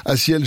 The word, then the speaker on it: Asiel: Asiel, j'ai appelé Zé Avi tout à l'heure. Je vais vous appeler Asiel si vous le connaissez pas encore et si vous le cherchez, parce que ça s'écrit un peu spécialement A6EL, Asiel, pour cette chanson qui s'appelle excusez-moi, Soleil.